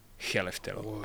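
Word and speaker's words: Skellefteå: Že třeba viděl jsem, viděl jsem dva zápasy ligy mistrů teďka a teda v tom druhém ve Švédsku, jinak jsem dlouho přemýšlel, jak se říká výslovnostně, a je to Cheleftilo. Cheleftelo.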